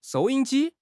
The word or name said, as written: 收音机